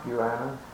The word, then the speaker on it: guano: Guana. – Guana sack. That's difference in our pronunciation. – I'll have to mark that down. That is a guano sack.